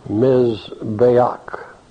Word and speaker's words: Mizbeach: The altar is the Mizbeach, M-I-Z-B-E-A-C-H, pronounced like M-I-Z, then B-A-Y, with accent on the bay, Ach, A-K-H, Mizbeach, an altar. The root word is Zabach, Z-A-B-A-C-H, pronounced Z-A-W-B-A-K-H, Zabach.